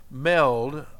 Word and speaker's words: meld: And you let all of those flavors cook and cook and melt and melt, melt, excuse me, meld, I didn't say it right, they meld together, they mesh.